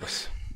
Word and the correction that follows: Cerberus: Cerberus. – That, that, maybe no, that. I, yeah, I said Cerberus, I Cerberus, and there's not an extra R in there.